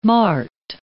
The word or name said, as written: mart